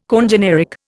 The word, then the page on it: congeneric: Indian